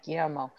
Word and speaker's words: Guillermo: Two L's, Y, Guillermo.